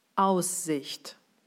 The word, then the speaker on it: Aussicht: Und in der richtigen Betonung wäre es dann Aussicht.